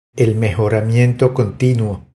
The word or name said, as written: El mejoramiento continuo